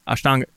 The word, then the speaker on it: Ashtanga: I don't think that's only specific to this Ashtanga. I don't know. I'm probably not saying that right. – No. That's right. Yeah. And it is, Ashtanga is just one style,